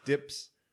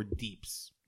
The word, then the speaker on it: DPS: At least in World of Warcraft, it's either DPS or deeps. DEEPS. Deeps. – Oh, that's how. I mean, okay. You spell it as DE, like the word deeps, but it's referred to DPS.